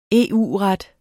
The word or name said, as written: EU-ret